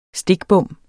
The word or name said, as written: stikbom